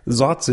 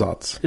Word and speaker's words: Saaz: Zotz is the one you see spelled S-A-A-Z, and it's pronounced Zotz. – It's probably pronounced, if you want to be totally accurate, like Zotz, Zotz, or something like that.